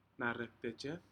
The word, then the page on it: narectejed: Aneityum